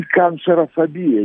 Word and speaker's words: канцерофобия: И канцерофобия, единственная фобия, которая имеет ударение на фобия, это, собственно, боязнь рака.